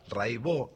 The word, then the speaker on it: Raibaut: Se escribe Raibaut, métanse, búsquenla, Rai con Y, Baut con B larga. Catalina Raibaut, Raibó se dice.